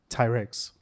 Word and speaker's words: Ty Riggs: Ty Riggs.